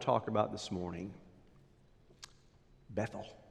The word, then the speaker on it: Bethel: Now, in Texas, we pronounce it Bethel. The Israelites, they still pronounce it to this day, Bethel. The reason is, it's a compound word, two words, bet, B-E-T-H, their H is silent. So it's Bethel, L is the prefix for God, the word bet is house, house of God.